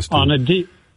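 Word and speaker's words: Stubb: I heard Finland Stube, I think it's pronounced. – Yeah, Alexander Stube.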